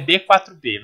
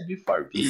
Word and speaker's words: B4B: Então é B4B, mas a pronúncia é b 4 B.